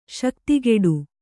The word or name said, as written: ಶಕ್ತಿಗೆಡು